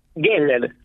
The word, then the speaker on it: Heller: Geller. Geller. Con G.